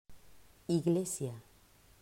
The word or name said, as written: Iglesia